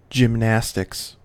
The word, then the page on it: gymnastics: American English